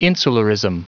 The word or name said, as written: insularism